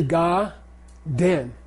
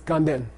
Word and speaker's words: Ganden: Like Ganden is really Ga-den. Ganden Monastery. There's no N. There's no N. It's a prenasal. It's a Ganden. The N belongs to the second syllable. It's Ga-den. And then when you say it fast, it's Ganden.